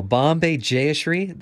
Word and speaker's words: Bombay Jayashree: Bombay Jayashree, the vocalist.